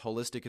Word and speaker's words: holistic: And yes, holistic, it's a big word. It sounds like the word holy, but it actually is more associated with the word whole, with W-H-O-L-E.